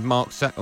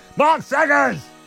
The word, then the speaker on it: Saggers: On to the Sunday exclusive now with David Connolly alongside Mark Saggers. Oh, no, I've said that wrong, haven't I? – Mark Saggers! – Is how you say his name correctly.